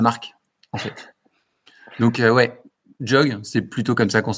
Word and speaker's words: JHOG: Comment est-ce qu'on prononce C'est quoi le ce qui fait vibrer tes clients En général, on dit Jog. J'ai toutes les prononciations existent. on a du Jog, on a du J-Og.